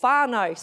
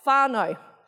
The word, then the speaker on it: whānau: Like the word family in Māori is whānau. So we're going to put that word up. I'll show you how it's spelt. Oh, there it is on the side. Whānau.